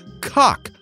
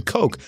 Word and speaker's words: Koch: Bobby McDee said, the surname Coke is derived from the German for cook and is more properly pronounced cock rather than Coke.